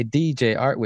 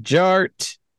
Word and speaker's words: DJ Art: And my name is Jared, aka DJ Art, with two T's for a double dose of that tink tink. The D is silent, so it's just Jart.